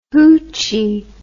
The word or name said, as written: hootchee